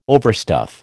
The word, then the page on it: overstuff: American English